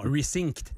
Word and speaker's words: Resynced: Resync. Non, resynced. – Resynced.